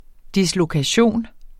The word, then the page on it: dislokation: [ dislokaˈɕoˀn ]; [ ˈdislokaˌɕoˀn ]